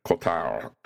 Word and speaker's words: Cotard: It is named after French neurologist Jules Cotard. Cotard. Jules Cotard. This is how we pronounce that.